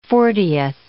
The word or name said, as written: fortieth